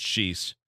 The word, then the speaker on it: sheesh: Sheesh isn't pronounced as just sheesh. It's sheesh. – Oh, yeah. – Higher or lower tone. – Yeah, it would. – Starts in a higher pitch, then long fades at a lower pitch.